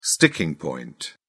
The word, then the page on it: sticking point: British English